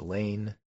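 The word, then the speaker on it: Lahn: New governor poll in Iowa, Rob Sand, 48, Zach Lane, 44. That's L-A-H-N, pronounced Lane, 44.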